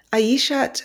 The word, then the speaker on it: Aishat: My guest today is Aishat Akanbi. Am I pronouncing your name correctly? – Yes, well, pretty much. The T is silent, so it's Aisha Akanbi, but you have my surname completely correct.